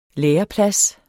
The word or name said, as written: læreplads